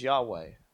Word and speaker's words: Yahweh: Yeah, Y H W H. But our pronunciation and how we pronounce it today is Yahweh.